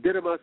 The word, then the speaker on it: dynamis: And so you call it dynamis or thinamis, however you want to pronounce it.